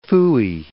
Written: phooey

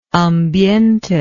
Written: ambiente

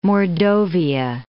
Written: Mordovia

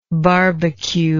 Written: barbecuer

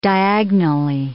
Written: diagonally

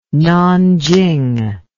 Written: Nanching

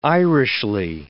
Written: Irishly

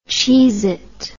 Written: Cheez-It

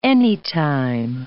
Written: anytime

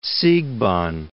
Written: Siegbahn

